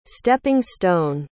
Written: stepping-stone

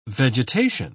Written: vegetation

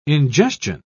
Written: ingestion

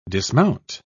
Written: dismount

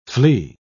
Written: flea

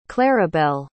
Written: claribel

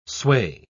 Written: sway

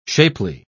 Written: shapely

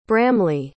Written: bramley

